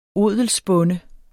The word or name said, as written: odelsbonde